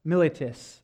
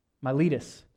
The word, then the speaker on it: Miletus: The following day we crossed over to Samos, and the day after we came to Miletus. I did not say that right. Miletus.